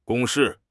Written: 公式

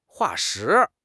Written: 化石

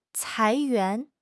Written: 裁员

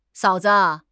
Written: 嫂子

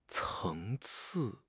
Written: 层次

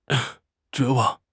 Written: 绝望

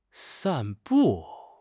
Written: san4 bu4

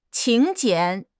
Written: 请柬

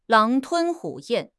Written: 狼吞虎咽

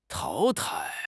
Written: 淘汰

